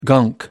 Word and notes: gunk: American English

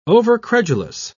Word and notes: overcredulous: British English

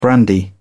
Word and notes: brandy: British English

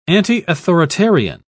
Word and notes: antiauthoritarian: British English

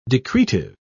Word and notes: decretive: British English

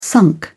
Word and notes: sunk: British English

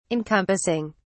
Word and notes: encompassing: British English

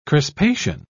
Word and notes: crispation: British English

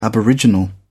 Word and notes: aboriginal: British English

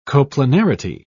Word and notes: coplanarity: British English

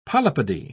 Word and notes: polypody: British English

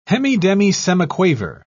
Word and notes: hemidemisemiquaver: British English

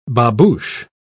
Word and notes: babouche: British English

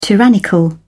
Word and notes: tyrannical: British English

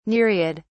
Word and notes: nereid: British English

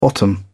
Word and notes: bottom out: British English

